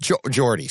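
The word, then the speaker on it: Geordie: She's like, so this email comes from Geordi, pronounced Geordi. Sorry, it says Geordi. It right after it. Geordi, but he's G-E-O-R-D-I-E. And he says, pronounced Geordi, as in Star Trek.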